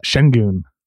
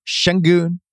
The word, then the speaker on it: Şengün: our guy, Şengün. Still got to figure out how to pronounce it. – Şengün. – Şengün.